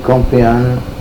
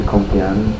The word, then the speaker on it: Compean: Chuy, C-H-U-Y, Compean, C-O-M-P-E-A-N, Compean. – Wait, you could just spell it again. – Compean, C-O-M-P-E-A-N, Compean.